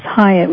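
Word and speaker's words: Hiatt: And, by the way, my name is Hyatt, which is with an I, H-I-A-T-T, not Y like the hotels.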